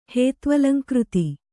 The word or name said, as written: ಹೇತ್ವಲಂಕೃತಿ